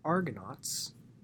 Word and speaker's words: Argonauts: And at that, we shall end this episode, and in the next episode, we shall actually talk about the Argonauts, that's how you pronounce it, and the quest for the Golden Fleece.